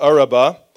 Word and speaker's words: Arabah: It goes down into the valley, which is called the Arabah. I think I'm saying that right. Yeah, the Arabah,